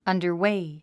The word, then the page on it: underway: ʌ̀ndə r wéi; アンダァウェイ